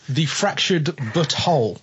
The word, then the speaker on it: The Fractured But Whole: South Park, the Fractured But Whole.